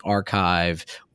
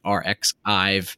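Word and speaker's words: arXiv: I never pronounced it Archive for like the first, like, I feel like month or two when I saw it was like RxIve. I did not get the pun there.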